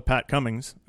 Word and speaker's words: Pat Cummings: So we're going to give a call to Pat Cummings.